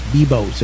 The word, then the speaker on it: Biblios: There is that one free one we talked about earlier called Bibos, B-I-B-L-I-O-S, like Biblios, but it's pronounced Bibos.